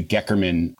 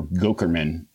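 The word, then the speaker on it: Goeckerman: the Gekerman treatment, and or Gokerman, depending on who is pronouncing it.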